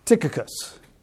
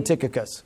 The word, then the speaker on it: Tychicus: English, Tychicus. Ancient Greek, Tychicus. So you decide which is easier for you to pronounce. I'm doing Tychicus.